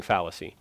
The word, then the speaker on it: fallacy: I always say fallacy. The suffering fallacy. Okay, it's a short A. Suffering fallacy.